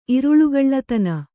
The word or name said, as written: ಇರುಳುಗಳ್ಳತನ